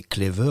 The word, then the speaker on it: Cleva: Euh, il y a des morceaux comme Clever, Cleva, Cleva qui s'écrit qui s'écrit Cleva mais c'est Clever en fait c'est euh, l'intelligence.